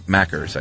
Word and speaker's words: Macca's: Matt says Macca's is McDonald's. Didn't know that. – It's not true. – He said it rhymes with Packers, Green Bay Packers, so Macca's, I guess.